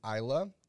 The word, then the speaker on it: Iyla: Her name is Isla. The song is Juice. It's spelled I-Y-L-A.